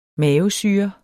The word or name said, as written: mavesyre